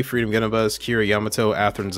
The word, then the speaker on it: Yamato: Yamato. – Yamato.